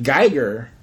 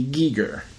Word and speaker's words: Giger: I've been pronouncing it Geiger. – Yeah, Geiger. – Yeah, and H.R. Geiger is what I used to pronounce it as. But I've heard from the contrary that it's actually Giger. – Because he is... – He's German.